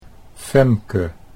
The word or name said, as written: Femke